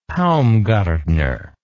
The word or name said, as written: Paumgartner